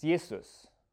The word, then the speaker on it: Jesus: But in German, a J is pronounced as a Y. It's Jesus. We just use the same spelling, but then pronounce it like it was English.